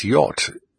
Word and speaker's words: yacht: How can you have dough, through, enough and cough all spelt the same way, O-U-G-H? And we have words with silent letters. We have words such as yacht, Y-A-C-H-T. And it's really, phonetically, it's Y-O-T.